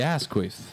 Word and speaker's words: Asquith: That's the actual pronunciation. – Where you spell it? – It's like Asquith. So it's Asquith. Asquith. Basically, there's no way you can say it where it doesn't sound like ass queef, okay?